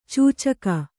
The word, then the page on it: ಚೂಚುಕ: cūcuka